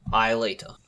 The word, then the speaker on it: Aelita: Aelita. I always make sure to pronounce the E in I.